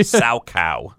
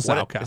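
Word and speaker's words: Salchow: Sow cow. – That's right. Triple sow cow.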